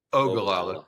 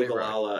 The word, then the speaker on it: Ogallala: Ogallala. Could you pronounce the weird names correctly? It's important. That's where my wife's family's from. So just get Ogallala right.